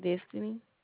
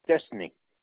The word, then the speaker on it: Destiny: My name is Destiny. – Wait. Spell that for me, please. – D-E. – D-E. Yes, ma'am? – S-T. – S-C? – S-T. – F. Wait. D-E-F-T? – S-T-I-N-Y. – Destiny.